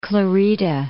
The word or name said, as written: Santa Clarita